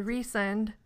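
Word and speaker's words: Rhysand: And then Reesend, like I just said it.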